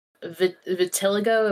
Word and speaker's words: vitiligo: And I would say that the most notable part about her is that she has vitiligo. Am I pronouncing that right? Vitiligo? – Yeah, I think so.